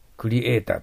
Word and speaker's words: creator: creator とか言ってます。カタカナで。そうじゃダメです。 R の発音だから、ベロが下に落ちて、喉の奥が相手に見えるような感じの発音にしないといけません。